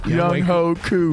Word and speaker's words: Younghoe Koo: Younghoe Koo.